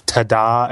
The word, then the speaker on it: Tada: It's called Tada SLR. – Can you say that right, please? – Ta-da! But it's ta-da with two A's at the end. So it's like really ta-da. It's like ta-da.